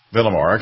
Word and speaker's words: Villamore: Villamore, okay. Just like it's spelled.